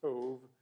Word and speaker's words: Tov: It's pronounced with a V and not a B. That's the Hebrew pronunciation, Tov.